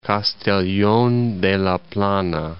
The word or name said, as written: Castellon de la Plana